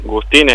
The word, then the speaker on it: Gustín: Juan Gustín es mi apellido. – Gustín, bien. – Con acento en ahí.